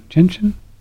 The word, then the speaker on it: gentian: The critical ingredients are the orange and the gentian, so you're good to go. – Is that, how do you say it? Gentian? Gentian? – Gentian, yeah.